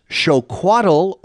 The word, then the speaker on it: xocoatl: It was even enjoyed by the Aztec emperor Montezuma, and the Aztec word for it, pronounced xocoatl or xocoalotl, evolved into the English word chocolate.